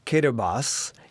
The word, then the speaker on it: Kiribati: It is now known as the Republic of Kiribati, K-I-R-I-B-A-T-I. And if you think about Kiribati, it's sort of the way that they pronounce in their language the word Gilberts, Kiribati.